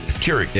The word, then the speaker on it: Keurig: Johnny brought in a coffee maker because Krieg. – Keurig. – Yeah. – Keurig. – Keurig. Yeah. You don't even know how to say it.